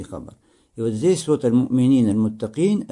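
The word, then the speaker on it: аль-му'минин (المؤمنين): Говорит он, что авлияуллах, угодники Аллаха, как уже было выяснено до этого, это верующий богобоязненный, аль-му'минун, аль-муттакун. Правильно говорить здесь «гум аль-му'минин, аль-муттакин». Хорошо, потому что «му'минин, муттакин» – это хабар для глагола «кана».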